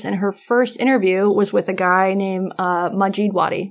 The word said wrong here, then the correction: Majdi Wadi: Majid Wadi? How do you pronounce it? – Majdi Wadi. – Majdi Wadi. – Yes.